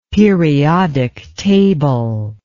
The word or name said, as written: periodic table